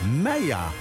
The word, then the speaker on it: Maya: Maya, voor de duidelijkheid. Even uitgesproken op die manier.